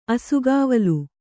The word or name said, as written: ಅಸುಗಾವಲು